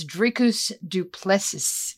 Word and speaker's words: Dricus du Plessis: And that's Robert Whittaker versus Dricus du Plessis, as Victor taught me. Did I say it right, man? – You did.